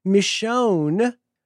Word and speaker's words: Michonne: Michonne rhymes with own.